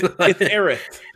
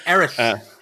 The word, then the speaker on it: Aerith: So it's Aerith. – It's Aerith.